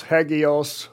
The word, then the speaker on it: hagios: Rick noted that the word for saint was translated from the Greek word that has been romanized in its spelling of hagios, H-A-G-I-O-S. That's the romanized spelling, and it's pronounced hagios or hagios, which is referenced by Strong's numbering system as number 40.